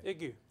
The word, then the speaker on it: aigu: It's an accent aigu. Is he pronouncing that correctly? – Aigu. – Yeah. Aigu? Yeah, aigu. It's close enough. – It's correct.